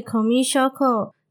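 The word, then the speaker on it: 古見硝子: 小小分享一下，古见同学的本名古见咲子的日文念法是 Komi Shoko，当中的 Komi 是外来语 communication 的缩写，而咲子的日文读音 Shoko 则有病症患者的意思。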